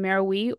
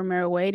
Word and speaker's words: Meroe: At the time, the capital was in Meroe or Meroe, depending on how you wish to pronounce it.